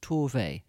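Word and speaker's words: Tove: Can't give the definitive, but having hung out with Sophia Jansson, Torve's niece, she's always said Torve.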